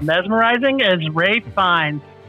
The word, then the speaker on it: Ralph Fiennes: Okay, Rafe. Rafe. Rafe Fiennes. – I don't know. I've got an uncle. He spells his name the same way. His name's Ralph. – My father-in-law is named Ralph, and it's spelled the same too, but whatever.